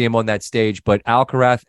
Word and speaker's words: Alcaraz: And Alcaraz, well, if you were from Latin America, it would be a hard Z. But if you're Spanish, the Z is TH, and he pronounces it Alcaraz. So that's how I said it.